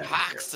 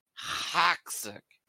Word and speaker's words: Hoxic: Which I Hoxic! – Care. – You say it like you're trying to spit on someone. Hoxic!